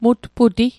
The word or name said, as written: mutputi